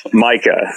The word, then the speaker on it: Micah: We weren't sure if it was Micah or Mieka or Maika or okay. So thank you for clarifying that. Micah.